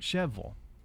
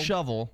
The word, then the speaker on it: Shelbyville: Just uh, down the road in Bedford County, we have Shelbyville, Shelbyville, Shelbyville, not Shelbyville, but Cheville.